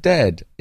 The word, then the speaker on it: dead: And dead, it's said like bed, not bead. For goodness sake, don't call it deed.